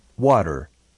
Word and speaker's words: water: Water. Agua. Acentúe fuertemente la primera sílaba.